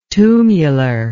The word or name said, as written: tumular